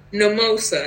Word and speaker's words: Mimosa: My name is Mimosa, and it rhymes with mimosa, and I can't do anything about it.